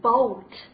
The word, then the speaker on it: boat: 啊，当我们发音的时候，同样的，大家注意一下，它是一个双元音，发音的时候必须要注意饱满，不能发成 o 啊。我们发一下注意口型的变化。 Oh，对不对？Oh， boat。好，说到这个 boat 呢，我们当然就知道，第一是船的意思，还有呢，你还可以就是乘船，我们叫 by boat，对不对？